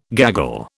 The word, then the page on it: gaggle: British English